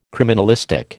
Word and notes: criminalistic: American English

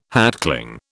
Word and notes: hatchling: British English

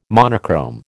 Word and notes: monochrome: American English

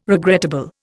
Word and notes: regrettable: Indian

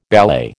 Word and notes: ballet: American English

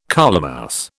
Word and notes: colemouse: British English

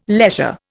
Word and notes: leisure: Indian

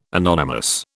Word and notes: anonymous: British English

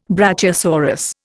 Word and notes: brachiosaurus: Indian

